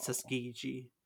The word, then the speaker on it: Tuskegee: but it is Tuskegee.